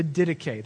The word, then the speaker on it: Didache: So this morning, we're going to look at the Didache. That's how you pronounce that.